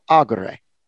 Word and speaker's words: agere: Well, for example, ago, I would say the infinitive will be pronounced agere. So it's kind of easy to do, just accent the third from the last syllable.